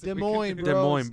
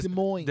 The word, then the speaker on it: Des Moines: Des Moines, bro. – Uncultured. – You America? – Oh, I'm sorry, I didn't say it with my Iowan accent. – No, that's how you say it. Des Moines.